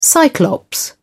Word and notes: cyclops: English; British English

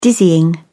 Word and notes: dizzying: British English

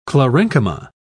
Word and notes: chlorenchyma: British English

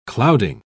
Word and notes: clouding: British English

